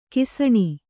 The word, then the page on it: ಘಸಣೆ: ghasaṇe